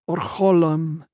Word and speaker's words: Orholm: "Father of lights, Holy One, Orholm," she aspirated the H, giving it the old pronunciation.